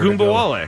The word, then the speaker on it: Ogunbowale: But you have to pronounce her name in order Ogunbowale.